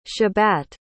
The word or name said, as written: xabat